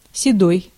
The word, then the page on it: седой: /sʲɪˈdoj/